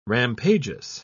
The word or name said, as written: rampageous